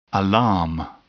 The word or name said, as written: alarm